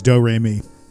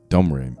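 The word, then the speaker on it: Domrémy: I think it's Domremy. Thank you. – It might be Domremy. – There's an M in there though. Domremy. – Domremy.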